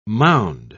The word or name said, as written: mound